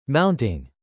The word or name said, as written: mounting